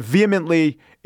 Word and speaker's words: vehemently: He also said vehemently is emphasis on the V.